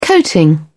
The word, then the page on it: coating: British English